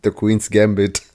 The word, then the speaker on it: The Queen's Gambit: Dann Annie Tyler Joy, ich hoffe, das war richtig ausgesprochen, hat in Split mitgespielt und in der Queen's Gebiet. Oder Gambit, der Queen's Gambit, ich weiß. – The Queen's Gambit.